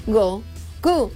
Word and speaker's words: Goku: Y la pronunciación en japonés de dicha fecha es Goku. O sea, el número 5 se dice Go y el número 9 se dice Q.